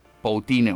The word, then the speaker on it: poutine: se pronuncia como el presidente de Rusia, pero se escribe Poutine, o sea, Putin.